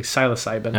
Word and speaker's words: psilocybin: psilocybin.